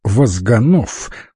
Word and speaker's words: Возганов: Бойкий толстячок Возганов с ударением на последнем слоге, обязательно подчеркивал он, некогда служил инструктором в одном из райкомов партии.